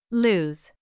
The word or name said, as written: lose